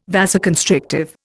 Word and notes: vasoconstrictive: Indian

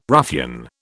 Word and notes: ruffian: British English

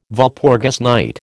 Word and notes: Walpurgis night: American English

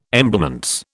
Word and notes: emblements: British English

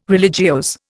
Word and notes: religiose: Indian

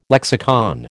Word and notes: lexicon: American English

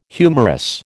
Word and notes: humorous: American English

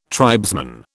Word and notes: tribesman: British English

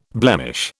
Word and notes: blemish: British English

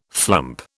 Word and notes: flump: British English

